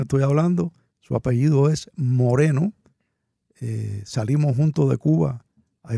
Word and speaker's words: Moreno: Era de apellido Moreno. Ay, y entonces Dios. Nosotros le decíamos Morino, porque en inglés se pronuncia Morino, y él fue al ejército.